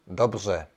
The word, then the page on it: hyvin: /ˈhyvin/